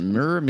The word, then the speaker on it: myrrh: Myrrh. How do you pronounce it? Is it myrrh? – It's myrrh.